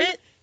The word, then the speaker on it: sherbet: There's not 2 R's. – No, there's not. – You say it correctly. – Sherbet? – Sherbet is correct.